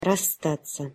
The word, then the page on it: rasstat'sja: Russian